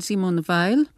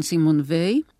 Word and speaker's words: Simone Weil: Ich habe dem Buch ein Motto vorangestellt, ein Zitat von der französischen Philosophin Simone Weil, richtiger ausgesprochen Simone Weil.